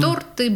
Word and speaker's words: торты: Торт, лифт, бант, где было ударение, во множественном числе там оно и остается. – Угу. – То есть торты, банты... Лифты. Все очень просто.